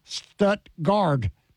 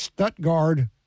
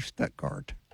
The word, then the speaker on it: Stuttgart: I didn't realize I was saying Stuttgart with a D. It's Stuttgart with a T. Stuttgart.